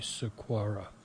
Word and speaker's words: Saquara: S-A-Q-U-A-R-A, Sequoia, I guess is a better pronunciation.